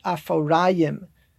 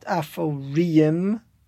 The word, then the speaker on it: Aforayim: By the way, how do you know to pronounce it Aforayim with a patach under the resh and not Aforiyim or something like that with a chirik? So when you have two yuds, you're generally getting the ah sound.